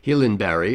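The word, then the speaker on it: Gyllenberg: Emma Nordenstam on cello, Ulis Gillenberg, or I suppose it would be Hillenberry if I were giving it a real Swedish pronunciation, on violin.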